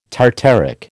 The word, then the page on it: tartaric: American English